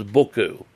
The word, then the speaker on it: buchu: known as buku, and I'll spell it for you, B-U-C-H-U.